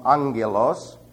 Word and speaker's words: angelos: The word angel is from angelos, And if you look up that word, it looks like this. And the double ramas there are actually pronounced ng.